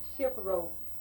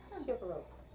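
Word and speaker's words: chifforobe: Shiprobe. It's spelled Chiffrobe. I believe that's the way it's spelled. Shiprobe.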